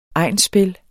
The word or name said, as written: egnsspil